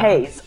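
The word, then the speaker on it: Heize: Is that how she pronounces it? Is it Heise? Does anyone know? Haze? Heise? Not sure. – I think the Korean looks like Haze to me. – Yeah. – Haze. Okay, let's say Haze.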